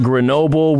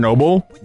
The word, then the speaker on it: Grenoble: Yeah, I went to Grenoble, which is where a big university is. So it's kind of an international. – You're saying Chernobyl? – Grenoble. Oh, yeah, Grenoble. Rhymes with Chernobyl.